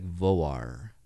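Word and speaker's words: Vágar: It's spelled V-A-G-A-R. But if anyone asks, if you ask anyone there, it's like Voar. It's like pronounced Voar. Mm. Voar, Voar, Voar. And so it's like not pronounced Vagar.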